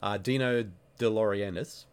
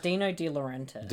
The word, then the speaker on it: Dino De Laurentiis: But producer Dino De Laurentiis, I probably butchered his name. Dino De Laurentiis.